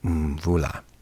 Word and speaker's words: Mvula: And Mvula, her last name is spelled M-V-U-L-A. And I thought, okay, I'm going to slaughter that one. So I actually looked up an interview with her where she taught us how to say her name, Mvula.